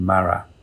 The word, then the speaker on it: Marat: And it's a painting that he did in 1793 called The Death of Marat. Actually, I've only just learned that's how you pronounce it.